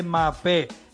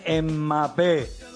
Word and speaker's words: Mbappé: Mbappé no, no se dice Mbappé, se dice Mbappé.